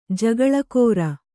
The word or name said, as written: ಜಕ್ಕುಲಿಗಾರೆ